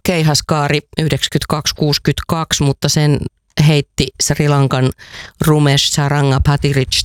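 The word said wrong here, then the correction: Pathirage: tai mä en osaa lausua hänen nimensä, mutta Pekka voi kohta kertoa, miten se oikeasti lausutaan. – Koska se Patirage. – Patirage, joo, no niin.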